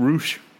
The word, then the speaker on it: Rush: It's just spelled like Rush, right? – Yeah.